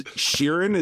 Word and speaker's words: Sheeran: Oh, is Sheeran? Is that how you pronounce it? Yeah, with the R that's in there.